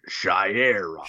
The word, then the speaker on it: Shayera: No, say it correctly. – Shayera. It sounds like back on our bullshit, back on our bullshit, back on our bullshit, back on our shit.